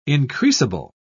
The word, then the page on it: increasable: British English